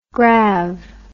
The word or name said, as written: grav